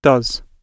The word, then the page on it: does: British English